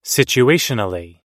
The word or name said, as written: situationally